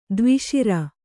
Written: ದ್ವಿಸರ

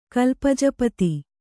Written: ಕಲ್ಪಜಪತಿ

